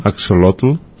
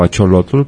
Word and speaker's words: axolotl: axolotl, o acholotl, como quieras pronunciarlo, o ajolote, y entonces, pues la gente que sabe dice que no sabe, que no sabe qué es, ¿no?